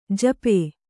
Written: ಝಾಪು

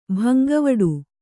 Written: ಭಂಗವಡೆ

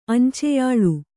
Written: ಅಂಚೆಯಾಳು

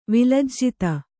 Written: ವಿಲಜ್ಜಿತ

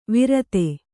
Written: ವಿರತೆ

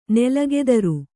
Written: ನೆಲಗೆದರು

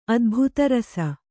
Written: ಅದ್ಭುತರಸ

